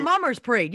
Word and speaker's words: Mummers: Have you ever heard of the Mamas in Philly? no. Parade? No, no. I guess they were like a. – Group. Oh, the Mummers Parade. Yes, yes. Yeah, I'm not saying it right.